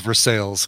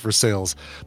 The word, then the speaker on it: Versailles: It's spelled the same as Versailles in France. – Yeah. – Where's Versailles? Because it's stupid that it's printed in the U.S. It's pronounced Versailles.